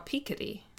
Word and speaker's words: Piketty: Well, so he's French. I believe it is technically pronounced Thomas Piketty. – Oh, okay. – But, so I call him Thomas Piketty. – Okay. I just keep hearing his name pronounced as Piketty. – Or Piketty, I've heard too.